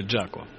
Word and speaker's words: Jaco: Кстати, он уже правильно произносит, как считаешь, Жако или Джако? Джако. – Ну, наверное, я не эксперт в плане произношения английского языка, но то, что я слышал, в принципе, он урожденный Джон. И тогда Джако. – Джако, да, это было прозвище кого-то из бейсболистов на самом деле. Писалось это изначально J-O-C-K-O. То есть, в принципе, Джоко. Но да, так как он жил в Флориде, то... – Типа Боб Дилан. – Да, с учетом их произношения, это читалось именно так. А тот вариант, который нам всем известен, 4 буквы J-A-C-O,